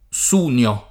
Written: Sunio

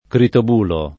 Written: Critobulo

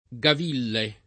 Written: Gaville

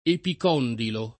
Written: epicondilo

